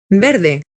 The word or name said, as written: y verde